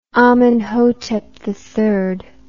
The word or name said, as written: amenhotep iii